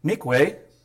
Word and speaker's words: mikveh: Mikveh actually is pronounced Mikveh in Hebrew.